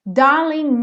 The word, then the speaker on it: darling: Darling knew. Aqui a mesma coisa aconteceu no darling. Como o G não é pronunciado, o N já juntou no you.